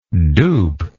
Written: dupable

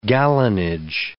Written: gallonage

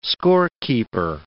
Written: scorekeeper

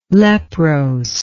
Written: leprose

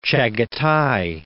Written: Chagatai

